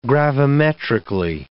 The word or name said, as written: gravimetrically